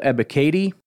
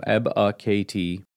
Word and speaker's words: Ebiketie: Next up, pass rusher Arnold Ebiketie. That is how you pronounce it, by the way. Katie is in the lady's name. Ebiketie.